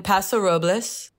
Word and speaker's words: Paso Robles: I'm in Paso Robles.